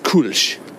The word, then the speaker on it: Kölsch: It's pronounced Kölsch. In America, a lot of people know it as Kölsch beer, but what it is there's this culture that only exists in Cologne, Germany, where you drink beer by the, 0.2 liters? – Mm-hmm.